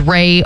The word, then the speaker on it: Rae: Her name is Ray, R-A-E.